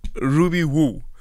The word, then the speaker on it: Ruby Woo: People are like, no, it's not Ruby Red. It's Ruby Who. Did I pronounce that correctly? Ruby Woo. It's Ruby Woo.